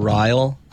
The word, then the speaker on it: Ryall: So it's my first name, Ryle _ climbs, but Ryle is spelled R-Y-A-L-L.